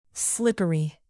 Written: slippery

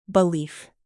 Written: belief

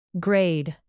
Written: grade

